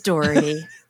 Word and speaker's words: Daugherty: It's Daugherty. Like Daugherty. – Like Daugherty so, Ferry Road. You know? – Oh, yeah. Well, you know, I never knew actually how to pronounce Daugherty okay. Ferry Road. I've been calling it Daugherty.